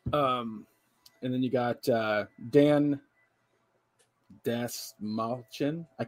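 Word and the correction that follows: Dastmalchian: Desmalchian, thank you.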